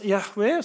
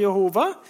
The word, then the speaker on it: Yahweh: Og så er det slik at dette Guds navn, som er disse fire konsonantene på hebraisk, og som vi, uttaler, vi tror skal uttales Yahweh, som noen uttaler Jehova, eh, det, det er et navn som i jødedommen ikke ble gitt til alle.